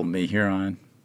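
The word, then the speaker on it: Mehuron: Yeah, or they just come to it and they go, me-hear-ons, something like that. – This is Tom. His last name adorns the sign on the front of the market, which means we've arrived at the answer to our pronunciation mystery. – And it's pretty simple, me-hyur-on.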